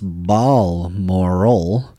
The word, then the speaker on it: Balmoral: Blamoral. Oops, no, Balmoral is actually... – I put the pronunciation in there. – I get it. – Balmoral. – And Balmoral is actually pronounced Balmoral.